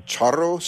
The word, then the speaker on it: charro: Is it a charro suit? Am I pronouncing it right? – Yeah. Mm-hmm.